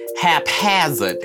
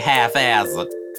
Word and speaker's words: haphazard: Well, I was told that I had trouble pronouncing haphazard, because apparently you don't say half-hazard.